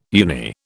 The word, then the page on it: uni: British English